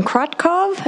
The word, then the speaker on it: Krotkov: My name is Jasmine Krotkov, and that's spelled K-R-O-T-K-O-V.